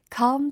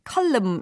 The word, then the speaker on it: calm: Come the light. C-A-L-M. 혹시 이거 발음을 column 이렇게 하시는 분들 계신가요? 땡 아니죠. 네, come이라고 발음해 주셔야 됩니다.